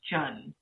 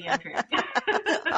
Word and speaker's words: Tran: In Vietnamese, it's pronounced Chun. – Chun. – It sounds like C-H-U-N. – Okay. – But I've gone by Tran all my life. – Okay. – So totally fine to say Tran.